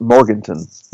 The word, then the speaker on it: Morgantown: Now, I don't know about the rest of the state of West Virginia, but I'm actually looking forward to that trip to Morgantown. That's going to be a good game, West Virginia, Kentucky. – I believe it's pronounced Morganton.